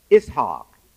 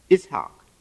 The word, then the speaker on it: Ishaq: And in the for the people of the book, the Jews and the Christians, they say Isaac, Ishaq, his name in the Quran, Ishaq.